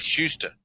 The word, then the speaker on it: Shuster: Is Schuster, is that the name you're saying? – Yes, Schuster, yes. S-H-U-S-T-E-R is how it's spelled.